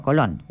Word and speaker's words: Colón: Colón no, Colón. Willy Colón. No sé por qué digo yo Colón. Si es Colón, tiene su acento en la O, como Cristóbal, ¿verdad?